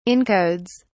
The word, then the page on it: encodes: British English